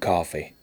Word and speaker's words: Coffey: John Coffey. Like the drink, only not spelled the same.